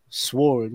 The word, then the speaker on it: sword: Sword word. I like sword. Cause why would they put the W in there if I'm not to pronounce it? – Right? – Because the W is silent.